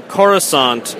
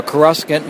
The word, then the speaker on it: Coruscant: Well, I did the dictionary pronunciation, which is Coruscant, which means glittering.